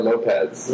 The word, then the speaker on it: Lopes: Lopes or Lopez? – Lopez. – It's spelled L-O-P-E-S.